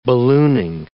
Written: ballooning

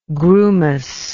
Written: grumous

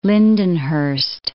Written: Lindenhurst

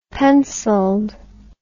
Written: penciled

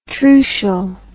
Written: trucial

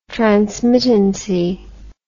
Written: transmittancy

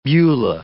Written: Beulah

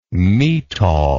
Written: metol

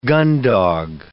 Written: gundog

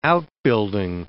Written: outbuilding

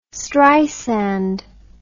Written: streisand

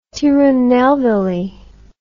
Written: Tirunelvel

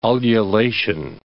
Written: ululation

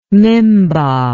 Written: mimba

